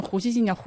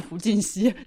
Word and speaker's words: 胡锡进: 所谓正此心事，波比直到最近才发现胡锡进叫胡锡进，不是胡进熙。